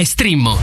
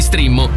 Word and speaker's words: Streammo: Entra adesso nel mondo di Strimmo, vai su Strimmo.it. Si scrive Streammo o scarica l'app.